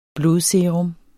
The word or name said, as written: blodserum